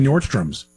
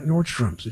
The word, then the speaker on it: Nordstrom: A lot of people say Nordstrom's. They put an S on there. – What did I say? – You said it correctly. I said Nordstrom. – Okay. – And so did John. But I'll tell you, people, Nordstrom's. It's not Nordstrom's.